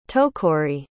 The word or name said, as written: tokori